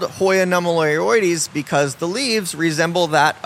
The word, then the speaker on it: nummularioides: Yes, so a Hoya that we sell is, it starts with an N, is pronounced Hoya nummularioides. Nummularioides.